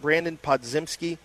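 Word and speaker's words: Brandin Podziemski: And how do you pronounce it? Brandon Podzimski? – Yes.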